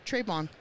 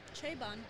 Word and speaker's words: très bon: How do you say it? Say it one more time. – très bon. – Trayvon. – Very good.